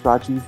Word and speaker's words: Rajiv: I like my name to be pronounced Rajiv Mahabir.